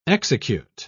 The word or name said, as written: execute